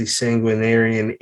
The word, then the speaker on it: Sanguinarian: It is The Sanguinarian Id.